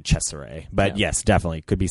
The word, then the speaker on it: Cesare: Well, when I look it up, because it's spelled C E S A R E, and then later in the film, once it's revealed that the doctor's inspired by this Caligari from Italy, I have to assume that it's Cesare. – Cesare, that's like the Italian. – Cesare. – Yeah, that's the Italian pronunciation, at least that I could find.